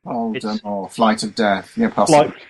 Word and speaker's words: Voldemort: I mean, you have to pronounce it Voldemort. The T is silent, as though he were French or something, which is news to me.